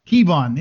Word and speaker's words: Kevawn: Kevawn, yeah, sorry, I'll get it.